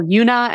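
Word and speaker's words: Juna: I am at The Official Yuna, and Yuna is spelled with a J on Instagram. And then I'm also on TikTok and YouTube, just my full name, Yuna Jada.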